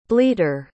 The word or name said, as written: bleater